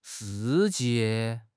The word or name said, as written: shi4 jie4